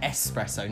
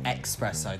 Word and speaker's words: espresso: I'm so glad you said espresso, not expresso.